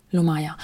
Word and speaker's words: lomaja: Spricht man das so aus oder würdest du es anders ja. aussprechen? Nee, genau so. – Lomaya.